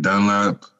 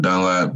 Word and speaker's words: Dunlap: My name is Broderick Dunlap. You spell that with B-R-O-D-E-R-I-C-K. Last name Dunlap, D as in dog, U-N-L-A-P.